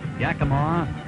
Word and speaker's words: Yakima: Well, he pronounced Yakima correctly, though. That's my favorite part of that old clip.